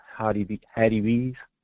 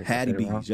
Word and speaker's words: Hattie B's: So to piggyback off your Hattie B's, that's how you say it. Sorry. – Hattie B's. Yep, Hattie B's.